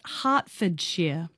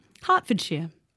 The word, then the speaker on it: Hertfordshire: Apparently it's pronounced Hertfordshire. Hertfordshire.